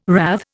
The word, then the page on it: rathe: Indian